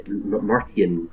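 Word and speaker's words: Martyn: And my name is Martin Bryson. Martin with a Y, by the way. And Marty N. – Yes. Martin.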